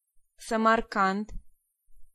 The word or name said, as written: Samarkand